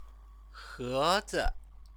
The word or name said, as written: hezi